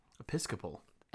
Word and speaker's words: Episcopal: Episcopal.